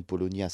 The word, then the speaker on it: Paulownia: Alors, pour indication, Polonia s'écrit P-A-U-L OWNIA, un arbre, donc je vous le disais, à la croissance ultra rapide.